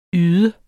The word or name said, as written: yde